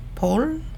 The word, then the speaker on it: Paul: Ich heiße Edith Paul, richtig ausgesprochen, nicht Paule, wie es die meisten tun, aber ich bin Mutter, Aktivistin und Künstlerin.